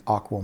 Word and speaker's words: AQAL: And we abbreviate that AQAL. We pronounce it the AQUAL model.